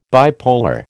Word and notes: bipolar: American English

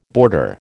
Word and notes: boarder: American English

